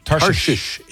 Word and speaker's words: Tarshish: Hopefully I'm pronouncing this right. I think it was at Tarshish. Am I saying that right? – Tarshish is correct.